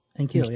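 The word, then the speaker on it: N'Keal: They're working this Alandon Roberts at fullback thing that's helping their offensive game plan in Keel. Who I pronounced his name wrong. What is it? – Enkeel, yeah. – Somebody told me I said it wrong.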